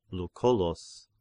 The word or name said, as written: Lucullus